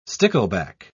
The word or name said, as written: stickleback